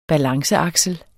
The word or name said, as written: balanceaksel